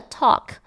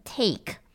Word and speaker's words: talk: 第一个 talk。这个字呢，很常被大家念成 take，他们的确长得是蛮像的，没错啦。